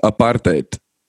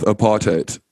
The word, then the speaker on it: apartheid: Well, the correct way to pronounce is apartheid. – Apartheid? Like that? – Yeah, but English people just tend to go with apartheid. So it's apart and then, the rest of the word.